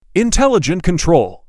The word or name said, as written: Intelligent control